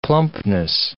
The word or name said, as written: plumpness